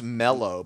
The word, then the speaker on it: marshmallow: It's mallow, but it's pronounced mellow. But it's spelled mallow.